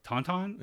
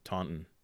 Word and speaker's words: Taunton: No, we pronounce it with a hiccup in the name Taunton. – Yeah, right? Yeah, right, exactly. – As if there's an apostrophe in the middle of it.